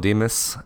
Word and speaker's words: Dimas: Hey, you can follow me on Instagram, Michael Dimas, MYKOL.